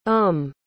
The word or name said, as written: homme